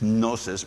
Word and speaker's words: gnosis: and I suppose I shouldn't be pronouncing the G, it's just gnosis, but you.